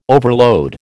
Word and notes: overload: American English

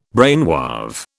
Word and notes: brainwave: British English

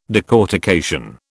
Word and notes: decortication: British English

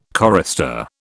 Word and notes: chorister: British English

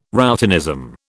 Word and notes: routinism: British English

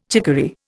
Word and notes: chicory: Indian

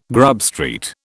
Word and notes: Grub Street: British English

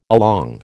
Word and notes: along: American English

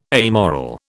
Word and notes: amoral: British English

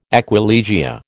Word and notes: aquilegia: American English

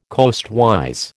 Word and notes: coastwise: American English

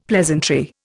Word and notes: pleasantry: Indian

